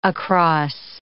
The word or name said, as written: across